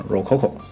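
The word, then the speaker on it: Rococo: 我们今天就要结合着啊，爱丽森二零一三展上一款热门游戏啊，热可可啊，不是饮料热可可，是洛可可啊，但是它的英文发音确实叫热可可。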